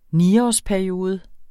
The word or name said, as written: niårsperiode